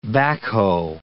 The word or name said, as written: backhoe